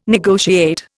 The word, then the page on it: negotiate: Indian